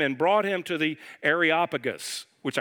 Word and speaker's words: Areopagus: The Areopagus, I kept adding 2, 3, 4 syllables to it, and Areopagus, there it is.